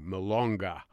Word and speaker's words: Milonga: And the second piece of music is called Milongo, or Milonga. That's, I'm sorry, I pronounced it wrong, Milonga.